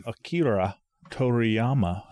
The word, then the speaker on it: Toriyama: Akira Toriyama. Hey, I said it right without reading it spelled out phonetically. – The guy who created Dragon Ball died.